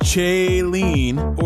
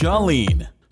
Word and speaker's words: Chalene: Welcome to the Chalene Show. Your host has one of those names that's difficult to pronounce. It's not Chalene or Charlene. Nope. Ladies and gentlemen, it's pronounced Chalene.